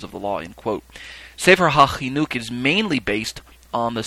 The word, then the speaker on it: Sefer HaChinuch: The next term on my list is Sefer HaChinuch, And sometimes you'll see it spelled with a C-H-I, and other times you'll see it just spelled with a H-A, say for Hahinuch.